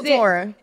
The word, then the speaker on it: Coachella: Coachella.